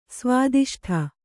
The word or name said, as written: ಸ್ವಾದಿಷ್ಠ